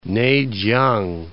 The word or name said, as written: Nei-chiang